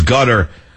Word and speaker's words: Qatar: I still can't pronounce that country. It's Qatar, it's Qatar, it's Qatar, it's gutter.